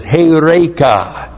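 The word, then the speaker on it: heureka (εὕρηκα): The perfect active indicative of heurisko is heureka. This is what we have. Heureka. H-E-U-R, long E-K-A. Heureka.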